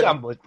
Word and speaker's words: Deion: Deion Sanders. Wait, am I saying that right? Yeah, yeah. Deion played football.